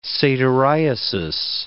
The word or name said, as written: satyriasis